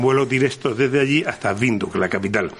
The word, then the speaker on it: Windhoek: Por cierto, aprovecho ahora para decirte que el nombre de la capital de Namibia se pronuncia Windhoek. No es Windhoek, ni Windhoek, ni nada por el estilo, es Windhoek. Otra opción es hacer escala en Sudáfrica, en Johannesburgo.